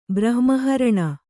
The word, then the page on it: ಬ್ರಹ್ಮಹರಣ: Kannada